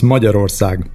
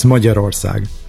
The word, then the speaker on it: Magyarország: Yes, it's M-A, and then there is a G and Y together, which you pronounce as G, but that's not part of the English alphabet, so good luck. It's Magyarország.